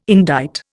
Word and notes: indict: Indian

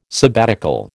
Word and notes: sabbatical: American English

